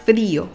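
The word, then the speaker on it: frío: Pour froid, on dit frio. Frio. En accentuant aussi sur le i.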